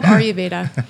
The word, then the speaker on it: Ayurveda: So it's called Ayurveda.